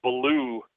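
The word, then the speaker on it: Bellew: Tony, I believe it's pronounced Ballou, beat David Haye, was a bit of an upset.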